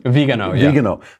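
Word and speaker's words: Viganò: Now the New York Times, former newspaper, reported this today as Vigano, is that how you pronounce it? – Vigano.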